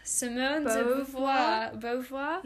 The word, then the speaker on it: Simone de Beauvoir: Simone de Beauvoir? – Yeah.